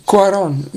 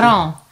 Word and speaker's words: Cuarón: Is that how to pronounce the name, by the way? – I don't know. Cuarón? Yeah, they're from Mexico. I don't know how you... Cuarón is how I say it. – Cuarón. – C-U-A-R with an, and then an O with a thingy on it.